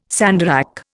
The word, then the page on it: sandarac: Indian